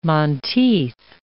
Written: monteith

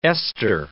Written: ester

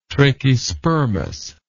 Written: trachyspermous

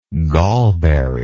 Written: gallberry

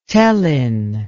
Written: tellin

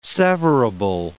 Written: severable

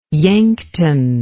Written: yankton

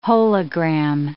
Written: hologram

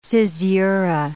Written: caesura

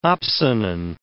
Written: opsonin

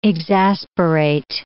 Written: exasperate

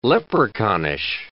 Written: leprechaunish

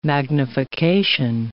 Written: magnification

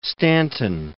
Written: Stanton

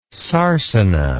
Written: sarcina

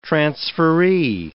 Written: transferee

